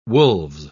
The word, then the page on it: wolves: British English